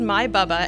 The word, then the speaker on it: My Bubba: Another one of the groups that you mentioned to me, is it My Bubba? Is that correct? – That is correct, and I actually had to check on the pronunciation. So the group is called My Bubba, and what that actually comes from are the two women, one from Sweden and one from Iceland.